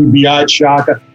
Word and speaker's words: Biaz Shaka: Let me make this easier on your audience, though. My name is actually Biaz Shaka. Just so you don't think you're pronouncing an appetizer on an Italian menu, my friends call me Bill.